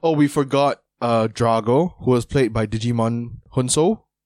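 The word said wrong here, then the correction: Djimon: Yeah, no, I always butcher his name. I think his name is Gmon Hunso. – Right, okay. – But it's spelled with a silent D.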